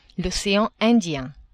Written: ocean Indien

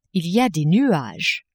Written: il y a des nuages

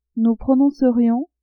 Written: nous prononcerions

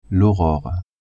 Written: aurore

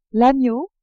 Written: agneau

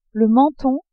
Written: menton

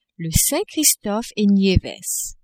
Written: Saint-Christophe-et-Nieves

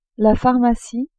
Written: pharmacie

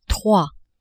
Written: trois

